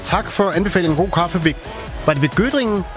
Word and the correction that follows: Göttingen: Nej, Göttingen.